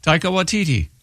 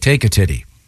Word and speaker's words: Taika Waititi: Taika Waititi. – Okay. I thought it was like Take a Titty.